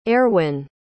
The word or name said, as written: Eirwyn